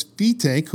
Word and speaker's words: Fetaque: In regards to the pronunciation of his name, from the January 1938 issue of the Linking Ring magazine, it says Fetaque, who insists that his name be correctly pronounced, like B Take, is a strong believer in showmanship in preference to a stage full of apparatus, and how well he proves his point.